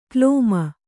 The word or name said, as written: ಕ್ಲೋಮ